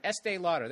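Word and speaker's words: Estée Lauder: Estee Lauder.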